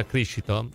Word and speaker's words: Criscito: Intanto salutiamo Palladino, salutiamo Sapete che si pronuncia Criscito? Criscito.